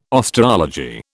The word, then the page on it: osteology: British English